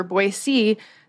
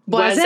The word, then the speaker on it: Boise: And they saw the trees and they exclaimed, les bois, les bois. And of course, les bois, B-O-I-S. But then if you add the E for Boise, doesn't that make a Z sound? It makes that like the French would pronounce Boise. – It. – Boise. Boise, exactly. Yeah.